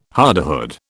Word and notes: hardihood: British English

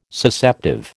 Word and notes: Susceptive: American English

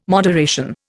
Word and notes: moderation: Indian

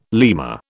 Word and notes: lemur: British English

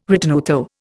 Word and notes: ritenuto: Indian

